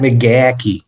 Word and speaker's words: McGachy: On est avec euh, Matt McGatch. Am I saying that right? Matt McGacky. – McGacky.